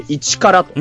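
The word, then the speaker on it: 1カラ: ファイターズの 2021 年のスローガンですね、うん、 01 カラットと書いて 1 カラというふうに、うん、読ませるスタイルでございます。うん、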